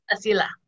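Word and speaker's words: Asilah: Asila.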